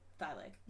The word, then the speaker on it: Thielen: How do you pronounce his last name? Thielen. – Thy leg.